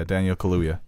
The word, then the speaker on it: Kaluuya: I think Daniel Kaluuya, did I say that right? – Oh, yeah, Daniel Kaluuya. – Kaluuya, yeah.